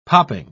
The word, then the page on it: popping: British English